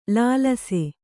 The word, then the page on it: ಲಾಲಿಸು: lālisu